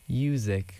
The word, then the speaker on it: Uziq: Uzik, but it's spelled U-Z-I-Q.